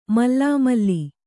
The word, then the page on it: ಮಲಮಲಿ: mala mali